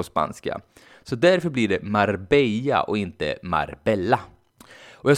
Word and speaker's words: Marbella: Jag åkte dit för att lära mig spanska och något av det första jag fick lära mig var att stan jag befann mig i inte uttalas Marbella utan Marbella.